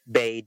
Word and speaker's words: Bey: As for myself, if you want to reach out to me personally, you can find me at Derrick Bay, DerrickIck.